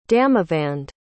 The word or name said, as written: damavand